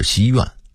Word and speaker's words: 西园: 西园的读音应该是西院，木樨地的读音应该是木樨地。